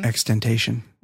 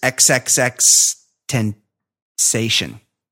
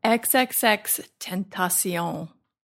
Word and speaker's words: XXXTentacion: Extentation. – No. – Extension. – No. Can I just do it? – It's XXX. You have to say all three X's. XXX Tentation.